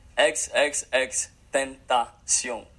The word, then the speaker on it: XXXTentacion: XXX Tentacion.